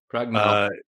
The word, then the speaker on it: pragmalchemy: Pragmalchemy. – Yeah. Well, how do you pronounce it? – I say pragmalchemy.